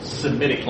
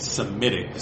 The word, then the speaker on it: Semitic: You can't say S-H-E-M. You can only have an H at the beginning of a word in Greek. So what do they do if they take a word that's spelled S-H-E-M and put it in Greek? They just leave the H out. It becomes S-E-M. So that's how you get Semitic.